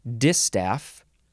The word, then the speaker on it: distaff: You emphasize the first syllable, distaff. D-I-S-T-A-F-F.